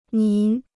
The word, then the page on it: 您: nín